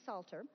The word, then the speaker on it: Psalter: Some of you may see the word Psalter when you're referring to the whole book of Psalms, and that is another word that starts with that silent P, Psalter.